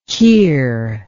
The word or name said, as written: keir